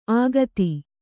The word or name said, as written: ಅಘಾತಿ